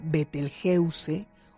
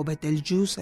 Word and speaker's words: Betelgeuse: Sin embargo, desde el mes de noviembre, diciembre, la estrella, una de las estrellas más importantes de esa constelación, que se llama Betelgeuse o Betelgeuse, algunos lo llaman, no importa, la pronunciación están todas aceptadas.